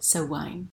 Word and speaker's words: Samhain: So it's actually written Samhain, but it's pronounced Samhain.